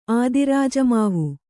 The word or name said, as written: ಆದಿರಾಜಮಾವು